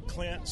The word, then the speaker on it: Clint: Clint, C-L-I-N-T, Herring, H-E-R-R-I-N-G.